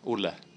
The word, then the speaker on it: Olle: my name is Olli Svensson, Swedish name, so if you can't pronounce it, that's fine. – What's your real name? – Olle. – Yeah, that's better, but I'll call you Olli.